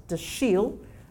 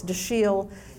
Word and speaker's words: Dashiell: So the name was D-E-C-H-I-E-L-L, I think two L's. And so the C-H is pronounced like Chicago, right? And if you pronounce it with a French pronunciation, it's Dashiell, right? So slight emphasis on the second.